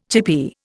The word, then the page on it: chippie: Indian